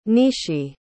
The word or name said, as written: nishi